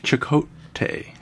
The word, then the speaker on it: Chakotay: Uh, Chipotle. It's hard to say his name. I was going to say Chipotle. I know. I try not to say Chipotle every time. I like Chakotay.